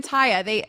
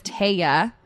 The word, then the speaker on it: Taya: So I've always been Taya. They originally, my name was Taya. Mm-hmm. But in Spanish, they couldn't, you know, pronounce it properly.